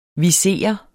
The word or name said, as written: viser